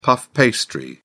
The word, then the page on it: puff pastry: British English